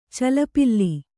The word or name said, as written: ಚಲಪಿಲ್ಲಿ